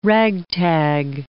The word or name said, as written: ragtag